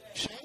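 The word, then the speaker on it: Hsieh: How do you pronounce his name? – Shay.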